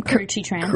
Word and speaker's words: Karrueche Tran: I mean, did he really think that he's going to be able to keep this out of the media and out of, how do you pronounce the girlfriend's name? – Karrueche Tran.